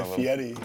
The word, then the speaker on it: Fieri: Fieri, that's how he says it.